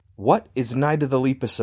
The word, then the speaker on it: Lepus: Interestingly, they pronounce it like if you were to call the rabbits leapers, but without the R. – The Lepus.